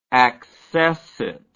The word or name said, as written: accessit